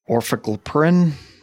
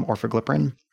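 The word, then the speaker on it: Orforglipron: Orfaglipirin is how you say the actual name, Orfaglipirin.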